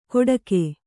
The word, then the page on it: ಕೊಡಕೆ: koḍake